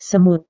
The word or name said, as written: semut